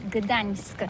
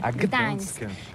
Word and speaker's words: Gdańsk: Oui, c'est pas très facile pour des étrangers, mais remarque bien que la langue polonaise, c'est la langue où on prononce chaque lettre qu'on écrit. C'est pourquoi on dit Gdańsk. – Gdańsk.